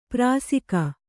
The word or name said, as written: ಪಾರಸೀಕ